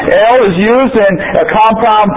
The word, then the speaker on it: El: El, pronounced El, occurs more than 200 times in the Old Testament, including these different compounds.